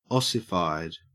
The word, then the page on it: ossified: Australian English